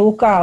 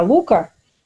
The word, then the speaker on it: Лука: Ну, для начала, насколько я понимаю, ударение на первом слоге. Все-таки он не лука, а лука.